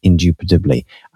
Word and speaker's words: indubitably: But because you don't really hear the first B, indubitably.